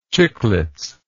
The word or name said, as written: chiclets